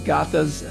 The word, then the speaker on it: Gathas: And he's also the author or translator of a recent publication of the Sacred Gathas of Zarathustra and the Old Avestan Canon.